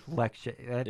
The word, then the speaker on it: Lecce: Just the name of it, Lecce. That is too funny. – It's even pronounced that way too.